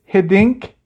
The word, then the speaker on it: Hiddink: Hiddink.